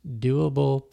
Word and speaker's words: Dewable: And the last one is Andrew Dew's Doable Premium Access. Since his last name is Dew, D-E-W, the program is called the Doable Premium Access. It's spelled D-E-W-A-B-L-E.